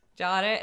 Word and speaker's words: Jare: Jare is an Assyrian word for poor thing, something you say when you're feeling sorry for someone. The J is pronounced almost like a SH and the R is rolled.